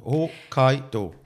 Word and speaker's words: Hokkaido: Es heißt nicht dann würde es H-O-K-K-A-I-I-D-O heißen, wenn es Hokkaido würde heißen. Aber es heisst Hokkaido. Mein Gott, ich habe das immer falsch gesagt.